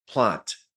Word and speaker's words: Plante: I'm excited to have on here today the great Tyler Plante.